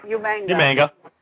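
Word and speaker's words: Umaga: You said it right, Umanga. – Umanga.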